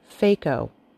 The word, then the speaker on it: phaco: The word parts meaning lens are both pronounced phaco. The spelling is P-H-A-C/O and P-H-A-K/O.